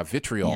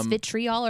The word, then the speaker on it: vitriol: So was it vitriol or is it vitriol? – I'm probably it's probably vitriol in British. You know, they add syllables. – Vitriol. I don't know. Oh no, I didn't realize that there was an I in there. Vitriol. – Yeah, there is an I in there. I always pronounce it vitriol.